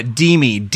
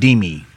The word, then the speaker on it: Dimmi: Yeah, Dimi, D-I-M-M-I, like the Italian word, Dimi.